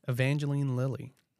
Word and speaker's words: Evangeline Lilly: Evangeline Lilly.